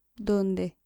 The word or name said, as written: donde